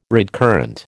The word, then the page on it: redcurrant: American English